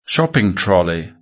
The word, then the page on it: shopping trolley: British English